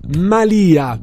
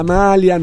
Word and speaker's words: malia: La malia di una strega, non la malia, non la maglia.